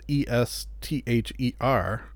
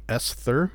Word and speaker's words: Esther: Even though it's pronounced, uh, spelled E S T H E R, you don't say Esther. You say Esther, which is, of course, Esther Povitsky.